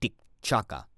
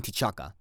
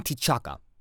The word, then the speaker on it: T'Chaka: Yes, the Dora Milaje, they are not forgetting what Zemo did to King T'Chaka. King T'Chaka. There we go. mispronouncing my words. King T'Chaka.